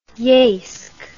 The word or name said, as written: yeysk